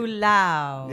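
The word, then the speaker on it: Fualaau: Since I'm familiar with the story, you say his name, Vili Faulo. Is that how you say it? – Faulo.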